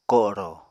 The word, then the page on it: ko7rò: Apatani